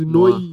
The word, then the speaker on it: noir: Is it Noir? Yeah, Noir. I always had problems pronouncing that word. – That's Noir. Yeah, but then, yeah, it's coming out very soon.